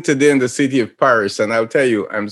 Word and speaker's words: Paris: Paris. – Paris. – No, Paris. You got to roll that tongue, buddy.